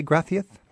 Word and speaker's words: gracias: Gracias, do you both say gracias? – Gracias. Gracias. That C is actually a TH sound, but we keep the S phonetically as the S sound, you know.